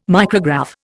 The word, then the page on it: micrograph: Indian